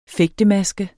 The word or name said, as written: fægtemaske